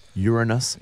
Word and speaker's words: Uranus: Uranus, etc. – Yeah. – Thank you for pronouncing that correctly.